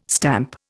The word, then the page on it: stamp: Indian